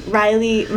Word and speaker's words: Rylee: So I was like, Rylee, my middle name is spelled R-Y-L-E-E, and I was like, that's unique.